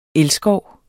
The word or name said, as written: elskov